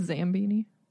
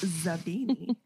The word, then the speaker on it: Zabini: Slughorn greets them, but particularly Harry and Zabini with extra enthusiasm because he remembers them from the Slug Club meeting on the train. – Don't you mean Zambini? – No, it's Zabini.